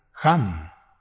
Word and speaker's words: Hang: Pronuncie el sonido mantran, ham. Se escribe H-A-N-G. La H se pronuncia como J porque es una raíz del idioma sánscrito, que también tomó el hebreo. La G debe pronunciarse guturalmente. Alargue la A y la N, también la G final.